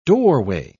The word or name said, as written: doorway